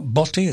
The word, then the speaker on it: Botti: His name is spelled B-O-T-T-I. It's not Botti, it's Botti.